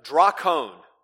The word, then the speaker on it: drakon: And the Greek term for dragon is drakon. Drakon, D-R-A-K-O-N. Drakon is how you would spell that in English letters.